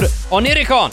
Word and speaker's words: Onirikon: It's called Onirikon. – Onirikon. You pronounced it a lot better than I did previously.